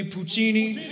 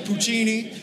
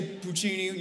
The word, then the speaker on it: Puccini: Say it with me, Puccini. You got to say it like this, Puccini. You can't just say Puccini. You got to use your, Italians got to use their hand, Puccini.